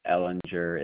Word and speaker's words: Ehlinger: And B-R-Y-C-E? Then, I'm sorry. – Correct. Yeah, correct. And then last name is Ellinger. It is spelled E-H-L-I-N-G-E-R.